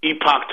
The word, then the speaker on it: Epoch: And a buddy of mine sent me an article from Epoch Times or Epic Times, depending on how you say it, that news outlet.